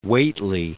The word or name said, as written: Whately